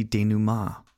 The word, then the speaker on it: denouement: Yes, I did have to have Google tell me how to say that because it is spelled D-E-N-O-U-E-M-E-N-T. So if you're trying to sound smart, but you've only read that word and you're like, the denouement, that's not right at all.